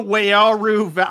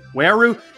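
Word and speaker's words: Wairau: No, it's also Wayaru. I just didn't know how to say it, really. So I didn't want to say like I said it wrong, but basically it's W-A-I-R-A-U, Wayaru.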